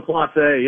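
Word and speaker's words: Clase: How do you say his last name? – Yeah, Emmanuel Colossi.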